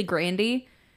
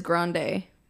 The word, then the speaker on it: Grande: Because like her last name is technically Grandy, but she pronounces it as Grande for like the music world because it's whatever.